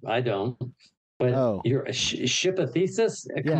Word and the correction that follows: Theseus: Uh, I pronounced it wrong. Theseus. It's Greek. Okay, well, explain that to me.